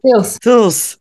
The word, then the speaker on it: CIRS: CIRS, danke. Ich wollte noch ein P hinten ranhängen, das wäre verkehrt gewesen.